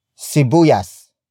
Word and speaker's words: Sibuyas: Sibuyas is spelled as S-I-B-U-Y-A-S. It means onion. The main topic of the sentence. We borrowed it from Spanish. Note the shift in which syllable is stressed. Most Tagalog root words are stressed on the second to the last syllable. In the case of two-syllable words, that would be the first syllable.